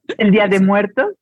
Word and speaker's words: Día de Muertos: Yeah, and Dia de los Muertos. Did I say that right? – El Día de Muertos. Yes.